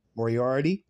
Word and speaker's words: Moriarty: Did I say it right? Kathy Moriarty? Okay, yeah. So she's a veteran actress.